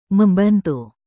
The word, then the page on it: membantu: Indonesian